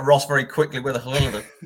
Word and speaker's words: Hladký: You've added a G in. Ross has got an N, stuck an N in there somewhere. We've got Hladký, Hladký.